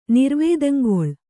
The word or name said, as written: ನಿರ್ವೇದಂಗೊಳ್